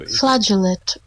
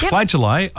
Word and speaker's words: flageolet: Now, there are a couple of different ways to pronounce this word today, Ali. – Okay. – I think I like the French-sounding one better. – Yep. – So, flageolet or flageolet.